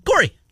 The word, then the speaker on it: Kaare: And his name, though pronounced Corey, I'm looking at pronunciation guide from at least college. – It's spelled. You wouldn't have thought it was Corey, would you? – K-A-A-R-E. – Mm-hmm. – Corey.